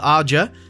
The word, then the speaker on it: Aja: And directed by Alexandra Aja. So that's really good. – Yes. – And folks, yeah, that's how you say it.